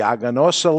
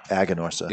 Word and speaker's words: Aganorsa: Aganosa. – Yeah, there's no R. – Aganosa. So how we do it here in New England is if it has an R, we skip it.